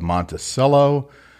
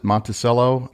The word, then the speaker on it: Monticello: Okay, so moving on from Moab, we're going to go straight south to the town of Monticello. I think that's the way you pronounce it. All the locals pronounce it Monticello. – Not Monticello.